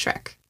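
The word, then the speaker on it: Schreck: It's S-C-H-R-E-C-K. I believe it's pronounced Shrek.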